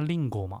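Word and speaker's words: りんご: 那苹果的日文发音叫令果嘛。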